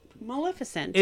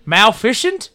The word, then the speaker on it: Maleficent: Maleficent. – It is Malef... How do you say it? – Maleficent. – Maleficent? – That's it.